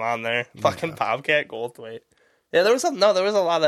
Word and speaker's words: Goldthwait: Is that how you say it? – Goldthwait? Yes, Goldthwait, yep.